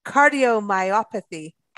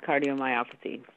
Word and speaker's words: cardiomyopathy: and today we're going to be talking about cardiomyopathy. I think that's a word, right? Welcome back. How are you? – I'm good. Thanks for having me. And you did say that right. It's called cardiomyopathy.